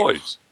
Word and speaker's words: buoys: What, how do you say it? – Boys. – They're boys? – Boys. – They're boys. – Then what do you need the U for? – I don't know.